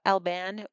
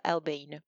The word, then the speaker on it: Albane: C'est très drôle parce que tu as hésité sur la prononciation de mon nom, comme à peu près les 100% d'Américains que j'ai pu rencontrer quand j'étais aux États-Unis. Donc, on peut dire soit Albane ou soit Albane, pour information.